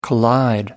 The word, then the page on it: collide: American English